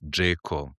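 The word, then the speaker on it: Džeko: Wie spricht man ihn nochmal richtig aus? Sag's mal. – Also mit dem Vornamen heißt der Edin. – Edin, ja. – Richtig. Und der Nachname ist Jayco.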